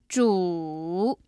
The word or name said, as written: zhu3